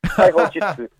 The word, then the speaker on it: Taiho Jutsu: How was my pronunciation? Is that what it is? – Afternoon. It was okay. Taiho Jutsu.